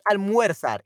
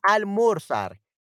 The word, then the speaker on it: almorzar: Okay, so it's not almuerzar, it's almorzar. So we changed the, the, the way that we spell it, unfortunately.